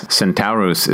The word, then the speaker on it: Centavrvs: So picture C-E-N-T-A-V-R-V-S. Centauros is how they pronounce it.